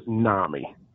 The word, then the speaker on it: NAMI: Because some people say NAMI, some people say NAMI. What is the correct? It, the correct pronunciation is NAMI.